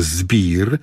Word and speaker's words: Zbeer: Zespół nazywa się Zbir, ale pisze się tak jak piwo. Z-B-E-E-R.